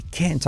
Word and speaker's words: can't: I can't talk right now. Fíjate que la T no la pronuncio y acentúo toda la palabra para hacer la negación.